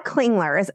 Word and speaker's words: Klingler: So Priscilla Klingler, am I pronouncing that correctly?